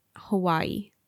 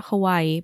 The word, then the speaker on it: Hawaiʻi: I think a lot of locals still say Hawaii, but the correct pronunciation is Hawaii. Mm. And there's a little, it looks like an apostrophe, but it's going the other way. That's called an okina between the two I's. And that is the proper way to spell it and the proper way to say it. So not Hawaii, but Hawaii.